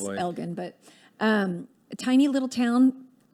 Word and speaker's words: Elgin: And I know it has should have a soft G. – Is it Elgin? G. I'm sorry. – No. It's just Austin mispronounces everything. It's a soft G because it's followed, G followed by I. It should be J. But we call it Elgin.